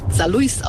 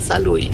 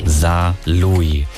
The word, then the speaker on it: Saarlouis: Das Saarlouis kommt vom französischen Louis, Ludwig. Und es wird meistens Saarlouis ausgesprochen von auswärtigen Fremden, was ich ganz schrecklich finde, weil das S nicht gesprochen wird. Also ein schöner Name, den man richtig aussprechen sollte, heißt Saarlouis.